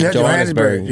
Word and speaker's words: Johannesburg: He was I hope I'm saying it right, Jonesburg. – Okay. – Yeah, and he's Johannesburg. – Johannesburg. I'm sorry, I've never been there.